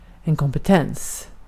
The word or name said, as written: kompetens